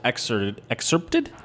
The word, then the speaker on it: excerpted: One of those essays was excerpted? – Excerpted, yeah. – I can never pronounce that word, and I thank you for doing it correctly.